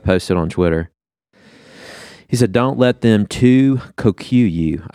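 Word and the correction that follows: tu quoque: I don't know if I'm saying that right, but that's what we're going to go with. I think it's Latin for like an illogical dissonance. Yeah, see if that's saying that right. – I think it's too quo quo.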